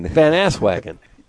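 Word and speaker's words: Van Aswegen: Lowercase V, Van. – Van? – A-S-W-E-G-E-N. – Van Asswagon. That's his name.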